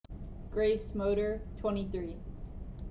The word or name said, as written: Grace Moeder